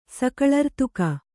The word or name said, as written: ಸಕಳರ್ತುಕ